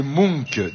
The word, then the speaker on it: Munch: Ça s'écrit M-U-N-C-H et je crois qu'on prononce Munch.